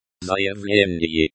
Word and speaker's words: заявление: Заявление. Заявление is a noun, and is pronounced with a stress on the second syllable, заявление.